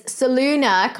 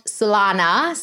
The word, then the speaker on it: Soluna: And I mean, your company, and I want to just like say it correctly, it's Soluna Computing, not Solana. So guys, it's got a U in there.